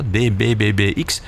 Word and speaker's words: DBBBX: Hun cd uit 2007, DBBX, is een... Of DBBB, ja, ik moet het juiste aantal B's uitspreken. DBBB. BBB, ja, DBBBX is een zijproject van de broers Johan en Peter Klaassen van Neven in samenwerking met anderen.